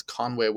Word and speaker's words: Conwy: next up on the list of the Northwest Four is Conwy Castle, and that's Conwy without an A, which is also a weird pronunciation. I used to call it Conwy.